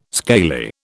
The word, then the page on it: scaly: British English